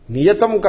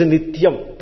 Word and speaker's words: nityam: Niyatam karma. Niyatam is nityam. Taya reversed, that is how it is. Nityam. Some people cannot pronounce nityam, you know. So they made it niyatam.